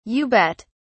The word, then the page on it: you bet: British English